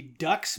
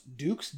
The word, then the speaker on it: Dux: So, before we even really meet Frank, we know the character that Jean-Claude Van Damme plays, Frank Dukes, which is spelled, it looks like it should be Dux, but it's pronounced Dukes, D-U-X.